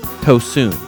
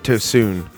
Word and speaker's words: Tosun: But they did not get Tosun right. – Tosun? They say Tosun. – Yes. – It is ooh. There is absolutely no question about what the letter U in Turkish is pronounced like.